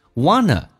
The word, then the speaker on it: wanna: Wanna, W-A-N-N-A, 记住是前鼻音，同时是 want to 的一个缩写，叫 wanna, 不要发成 want, 那是后鼻音 wanna.